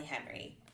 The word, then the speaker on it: Henery: This week, our special guest is Molly Henery. Is that how you say your last name, Molly? – Yes, it is.